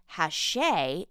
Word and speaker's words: Hachette: It's the publisher that is pronounced Hachette, H-A-C-H-E-T-T-E, but because it's a French word and we're in America, it should be pronounced Hachette. Oh, those French words always having consonants that are silent.